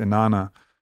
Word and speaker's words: Inanna: Now she's actually going even deeper and finding that potentially that energy is a Mesopotamian, I think, goddess Inanna.